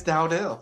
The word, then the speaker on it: Dowdell: Is it Dowdell? – It is Dowdell. Thank you for pronouncing it right.